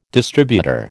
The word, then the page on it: distributor: American English